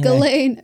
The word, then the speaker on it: Ghislaine: What's her name? Ghislaine, spelled like gazelle. Ghislaine. We've had too much wine. Ghislaine Maxwell.